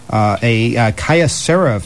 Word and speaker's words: Kyocera: Kyocera phone.